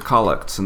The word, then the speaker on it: collect: This podcast is about the intersection of liturgy and life, as seen through the lens of the Collects in the Book of Common Prayer. Now, collect is kind of a funny-sounding word, but actually it's just another word for prayer, and it's spelled like collect,